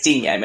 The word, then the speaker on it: Digne: So, also, Digne. I think that's how you pronounce it. – It's Digne, mate. It's Digne. – Digne. Well, there you go. He'll be now known as Digne.